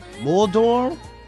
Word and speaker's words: Muldaur: Maria Muldaur? – What? – Muldaur.